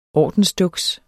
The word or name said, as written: ordensduks